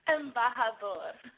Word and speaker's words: embajador: Embajador. – Embajador.